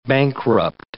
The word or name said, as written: bankrupt